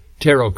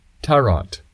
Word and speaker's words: tarot: Well, actually, I should say tarot cards. It's spelled tarot, but when I was growing up, I learned that it was pronounced tarot.